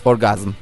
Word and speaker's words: orgazm: Marul der gibi söylüyorsun ya. Uzatma oradaki A'yı. – Orgazm.